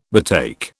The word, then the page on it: betake: British English